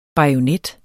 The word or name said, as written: bajonet